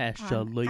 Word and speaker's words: Ashley: I don't know why I gave my name three syllables there, but Ashley.